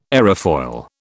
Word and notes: aerofoil: British English

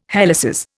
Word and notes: helices: Indian

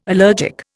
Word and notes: allergic: Indian

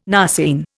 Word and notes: narceine: Indian

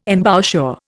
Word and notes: embouchure: Indian

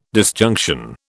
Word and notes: disjunction: British English

